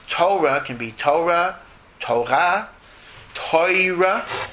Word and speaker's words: Torah: and Torah also is Torah, not Torah, because in Hebrew, most words are accented in the last syllable, not the first syllable.